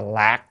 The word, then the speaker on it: lạc: Thì thấy chỗ này, mặc dù viết chữ nhạo nhưng mà coi kỹ lại nên đọc là lạc, tức là niềm vui.